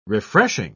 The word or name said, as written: refreshing